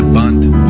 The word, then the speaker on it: Bund: And they were calling that party the Bund Party. It's B-U-N-D, but they pronounce it B-U-N-D-T in German.